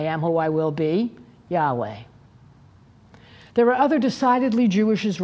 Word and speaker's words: Yahweh: One of the divine names of God is YHWH, which is pronounced Yahweh.